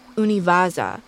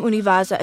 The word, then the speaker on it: UNIVAJA: which I believe is pronounced Univaza, noticed something odd.